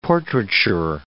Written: portraiture

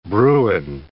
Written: bruin

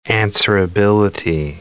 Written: answerability

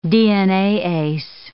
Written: DNAase

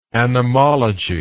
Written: anemological

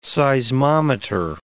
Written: seismometer

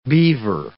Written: beaver

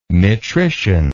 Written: metrician

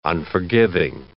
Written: unforgiving

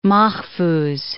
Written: Mahfouz

